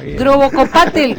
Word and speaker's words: Grobocopatel: Grobocopatel, con el acento en la A.